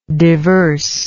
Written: diverseness

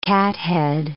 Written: cathead

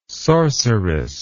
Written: sorceress

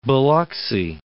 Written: Biloxi

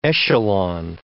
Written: echelon

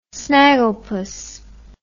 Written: snagglepuss

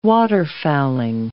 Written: waterfowling

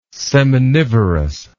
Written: seminivorous

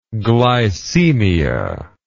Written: glycemia

